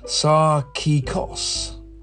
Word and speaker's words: sarkikos: Well, the Greek in Strong's Concordance again is G4559, and it's spelt, or it's pronounced rather, sarkikos.